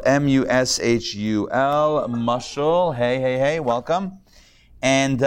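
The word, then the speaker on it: mushal (משל): So the Hebrew word for parable is mushal. Mushal. Mushal. Okay, if you want to spell that in Hebrew, it's three letters. Mem, shin, lamed. Mushal.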